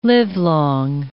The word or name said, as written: livelong